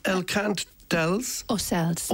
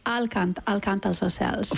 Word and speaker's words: El Cant dels Ocells: So it's El Cant dels Ocells. – Ocells. – El Cant dels Ocells. Except you say Al Cant dels Ocells.